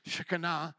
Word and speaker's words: Shekinah: The rabbi spoke so much about the Shekinah, how would you say Shekinah? Shekinah, but the Hebrew pronunciation. Shekinah. I think that's how you pronounce it with the Hebrew, the Shekinah. But thank you for the English version, the Shekinah.